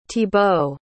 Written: thibault

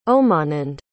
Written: Omanand

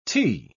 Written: tea

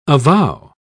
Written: avow

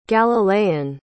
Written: galilean

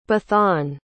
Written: beathan

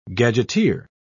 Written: gadgeteer

